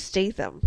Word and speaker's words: Statham: That was Statham. – Yeah, it is, but I was pronouncing it phonetically.